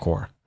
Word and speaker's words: Quora: Yeah, I call it Quora. – Okay. Do you ever go on that? – Sometimes. – Because sometimes I end up there when I'm looking. – Quora's like, it's like Yahoo Answers.